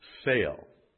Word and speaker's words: tsel: The word shadow is from the transliterated word sail. Now, it sounds like, you know, S-A-L-E, but it's not. It's spelled T-S-E-L and appears 49 times in the King James Version, all of which are in the Old Testament.